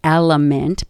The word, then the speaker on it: LMNT: I'd like to thank another sponsor of the podcast, and that's Element, pronounced Element, but actually spelled L-M-N-T.